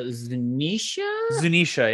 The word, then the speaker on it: Zunisha: so it was a Zhao with the elephant showing up out of the shadows. Holy crap. – Zunisha, yeah, that's it.